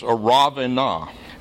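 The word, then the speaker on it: Araunah: Now, I have no idea why it's written this way in your English Bibles, but if you look at that word in your English Bible, see the U? Well, in Hebrew, that's a consonant, and it's got a vowel after it. So that's actually pronounced Aravenah.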